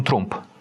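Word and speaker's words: Trump: Он такой, ну, может, в силу своего немецкого происхождения, потому что он не Трамп, он Трумп.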